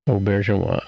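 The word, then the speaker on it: Auberjonois: Aubergeois.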